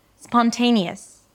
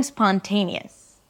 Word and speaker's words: spontaneous: So it's not spontaneous, ta-ta-ta-ta, but spontaneous. The te is the primary stress, so it's the longest syllable, and the us at the end is the shortest, so it's really reduced and short.